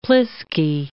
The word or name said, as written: plisky